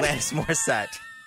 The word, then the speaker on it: Alanis Morissette: Alanis Morissette.